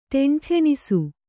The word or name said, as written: ಢೆಂಢೆಣಿಸು